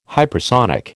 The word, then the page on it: hypersonic: American English